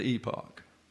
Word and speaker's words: epoch: The accent's on the second syllable, the epoch, not a long E, but a short.